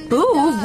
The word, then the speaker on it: Boov: Boov rhymes with groove.